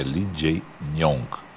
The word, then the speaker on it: Lee Jae-myung: E a gente aprendeu, eu pronunciei errado, é Lee Jae-myung.